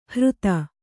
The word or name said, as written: ಹೃತ